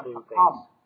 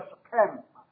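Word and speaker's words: Shechem: shakam 所以它演变成为后来有一个戏名叫事件的事件呢它的这个发音叫做 shakam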